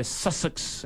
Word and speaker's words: Sussex: Sussex en Inglaterra.